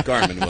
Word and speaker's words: Jarman: To have Ralph Jarman. – Garmin, Will. It's Garmin. – Hard G. – Hard G. I'm sorry. Yeah. I've always said Jarman.